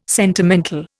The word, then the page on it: sentimental: Indian